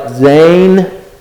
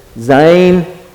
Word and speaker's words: Zayin: It's under the Hebrew letter Zayin, and this is how it's pronounced, like Zayin, Zayin. It sounds like a Z.